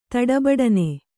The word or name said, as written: ತಡಬಡನೆ